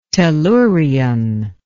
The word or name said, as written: tellurian